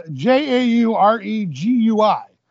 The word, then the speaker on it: Jauregui: So it's spelled J A U R E G U I, but it's pronounced how So we had Yazmin Jauregui versus Sam Hughes.